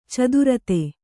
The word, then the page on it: ಚತುರ್ಥಿ: caturthi